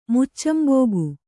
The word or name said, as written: ಮುಚ್ಚಂಬೋಗು